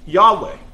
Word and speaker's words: Yahweh: And this means, tetragrammaton actually means four letters, and is pronounced Yahweh.